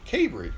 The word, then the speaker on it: Cabre: Am I pronouncing your name right? – Cabre. – Long A. Cabre.